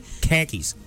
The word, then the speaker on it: khakis: You're saying it wrong. Khakis.